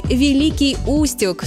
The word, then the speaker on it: Великий Устюг: Но запомните, что правильно говорить «Великий Устюг» с ударением на «у».